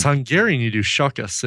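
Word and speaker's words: Csokas: Yeah, I don't think you don't pronounce one of those. You don't pronounce the C and the S. I think you just go Sokas.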